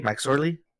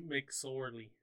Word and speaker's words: McSorley: Is that how you say it? Mike Sorley? Mike Lowry? – Mick Sorley. – Mac Sorley, sorry. – Mick Sorley, I think. I don't know. – Okay, Mick Sorley.